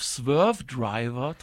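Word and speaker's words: Swervedriver: Aber die Frage ist, wir haben jetzt hier, das wolltest du gerade schon sagen, eine Version von Swerved River. – Einigen wir uns auf Swerve Driver, Torben. – Swerve Driver, so kann man das auch aussprechen.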